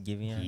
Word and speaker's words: Giveon: Yes, Giveon.